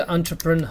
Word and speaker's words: entrepreneur: 注意它的发音叫 entrepreneur，很多人都不知道这什么东西啊。